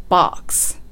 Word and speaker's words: box: ボックスと小さい 2 を入れずに box とね、ちょっと音を伸ばすような感じで発音すると英語らしくなるんですね。 a box ではなくて a box a box、こんな感じです。